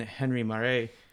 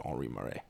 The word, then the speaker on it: Marais: A solution to that problem was discovered in 1857 when Henri Marais... – It's pronounced Henri Marais. – Henri Marais, whatever.